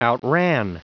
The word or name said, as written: outran